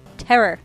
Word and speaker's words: (T)error: We saw Terror. T-Error. – T-Error? Yeah, I wasn't sure how I was going to pronounce that one.